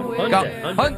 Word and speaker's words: Hyundai: Hyundai.